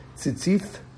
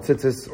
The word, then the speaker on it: Tzitzis: It's pronounced Tzitzith, because that's the way they rolled in the 80s. But now we call them Tzitzis or Tzitzith.